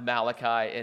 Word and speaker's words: Malachi: Um, it's, it's actually a Hebrew name, and, uh, and it's pronounced Malachi, and it means my messenger.